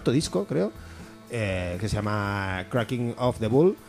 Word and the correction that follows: Bulk: The Bulk, perdón, con la K, no la he pronunciado.